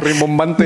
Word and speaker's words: rimbombante: Incluso llego a sonar rebombante. ¿Cómo se dice esa palabra? – Rimbombante. Rimbombante.